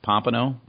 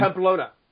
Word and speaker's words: Pamplona: Pompano? Is it Pompano, Spain? – Pamplona.